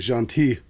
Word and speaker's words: gentil: I asked my daughter for pronunciation help on Gentil, and because she's an avid French student. She left the L off, basically. And I was like, how come you don't pronounce the L at all? And she said, I am pronouncing the L. And I said, you're swallowing the syllables, just like people I know that actually are native French speakers.